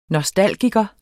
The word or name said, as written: nostalgiker